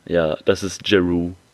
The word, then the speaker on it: Jeroo: J-E-R-O-O. – Giroux.